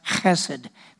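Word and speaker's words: chesed (חסד): It's a word called hesed. It's actually pronounced chesed. It's a guttural sound in Hebrew. It's an HSD.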